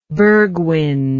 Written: bergwind

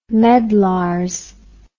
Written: medlars

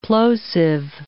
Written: plosive

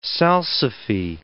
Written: salsify

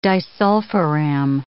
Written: disulfiram

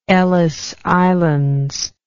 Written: Ellice Islands

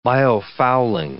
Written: biofouling

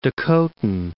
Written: Dakotan

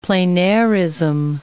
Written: pleinairism

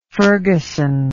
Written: ferguson

